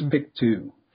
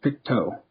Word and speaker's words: Pictou: I said it was Pictou. It is not. It's Pictou. So I was corrected in bold letters. How to pronounce that? T-O-E.